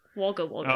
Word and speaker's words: Wagga Wagga: You just pronounce it Wagga Wagga.